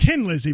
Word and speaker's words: Thin Lizzy: By Thin Lizzy. – Pronounced Tin Lizzy, but I'll give it to you.